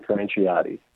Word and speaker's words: Franciotti: Telemarketers have been mispronouncing it throughout my whole life. It's Kevin Franciotti.